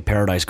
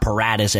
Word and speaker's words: Paradis: You look at Paradis going down. It's not Paradis, as the CBS broadcasters were saying, Steve Berline and those guys mispronouncing every single name.